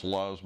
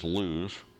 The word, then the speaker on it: Luz: I've heard that pronounced Luz, but the Hebrew word is Luz.